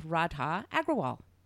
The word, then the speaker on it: Radha Agrawal: And then, you guys, I say we go right into this very spiritual interview with Radha Agrawal.